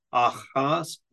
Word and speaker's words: Ahasuerus: It's Ahasuerus, which is not how I pronounced it either.